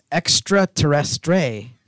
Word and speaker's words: extraterrestre: By the way, it's extraterrestre. That's how you say it. That's how you say it in French.